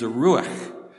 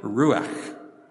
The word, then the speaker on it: ruach: The Hebrew word for breath of life is ruach, which is a great word because it takes a lot of breath to say it properly, ruach.